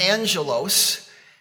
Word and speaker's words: angelos: In the biblical Greek, angel is pronounced angelos.